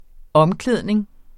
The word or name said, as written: omklædning